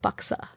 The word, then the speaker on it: buxar: B-U-X-A-R. But you don't pronounce the R. So it's like buksa.